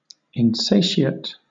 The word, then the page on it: insatiate: British English